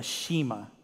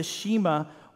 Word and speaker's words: Shema: Church, what Jesus just told them is what they would call, and what till today Jewish people will call, the Shema. Now, some of you may have never heard that word before. Some of you may have. It's spelled S-H-E-M-A.